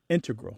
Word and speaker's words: integral: and math-using scientists are more likely to put, say, integral with the stress on the first syllable.